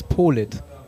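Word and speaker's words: Politt: Das heißt nicht Polit, das heißt Nils Polit.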